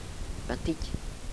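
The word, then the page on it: batic: dialetto croato di Lukezi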